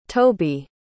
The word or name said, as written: tobe